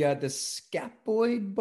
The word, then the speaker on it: scaphoid: So you broke the scaphoid bone? Did I say that right? – Yeah, which is like a really hard bone to heal.